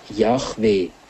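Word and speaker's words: Jahwe: Er wird aber in der allgemeinen Überlieferung als Yahweh ausgesprochen.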